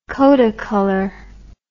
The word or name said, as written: Kodacolor